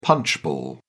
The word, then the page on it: punchball: British English